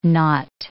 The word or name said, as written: knot